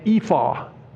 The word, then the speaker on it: ephah: Verses 5 and 6 speaks of a measuring basket, or your Bible might say ephah. That's how you pronounce it.